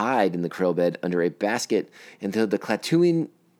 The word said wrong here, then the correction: Klatooinians: Jesus, I'm going to say this wrong all the time. It's Klatooinians, and I already said it wrong once.